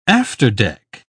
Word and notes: afterdeck: British English

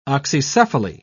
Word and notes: oxycephaly: British English

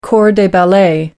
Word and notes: corps de ballet: American English

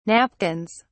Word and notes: napkins: British English

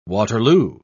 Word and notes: waterloo: British English

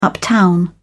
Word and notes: uptown: British English

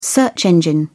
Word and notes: search engine: British English

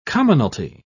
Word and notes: commonalty: British English

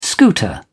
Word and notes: scooter: British English

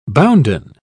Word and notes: bounden: British English

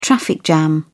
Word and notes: traffic jam: British English

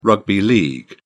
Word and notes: rugby league: British English

